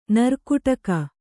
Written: ನೀರುಕುಟಿಗ

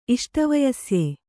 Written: ಇಷ್ಟವಯಸ್ಯೆ

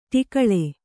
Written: ಟಿಕಳೆ

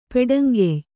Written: ಪೆಡಂಗೆಯ್

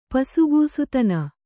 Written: ಪಸುಗೂಸುತನ

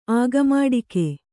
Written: ಆಗಮಾಡಿಕೆ

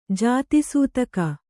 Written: ಜಾತಿಸೂತಕ

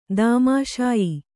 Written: ದೌಮಾಷಾಯ

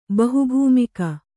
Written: ಬಹುಭೂಮಿಕ

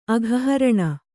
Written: ಅಘಹರಣ

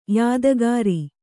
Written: ಯಾದಗಾರಿ

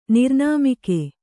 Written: ನಿರ್ನಾಮಿಕೆ

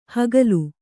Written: ಹುಗಿಲು

